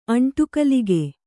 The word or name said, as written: ಅಂಟುಕಲಿಕೆ